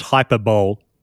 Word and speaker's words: hyperbole: So, I mean, when as a kid, I was one of those people who took a long time to realize it's not pronounced hyperbole. Hyperbole. – Yeah, same. – Like seriously, I was in my 20s before I knew the right way to pronounce that.